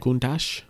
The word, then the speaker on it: Countach: How do you say it? – Countach? – Countach, yeah. – I believe.